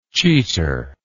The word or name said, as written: cheater